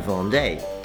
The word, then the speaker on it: vendent: And with the vous form, we add E-Z, vous vendez, V-E-N-D-E-Z. – So with the third person plural, il-elle, we add E-N-T to the stem, so it's spelled V-E-N-D-E-N-T, but pronounced vend. So il el vend, and the D is more pronounced than in the third person singular, vend.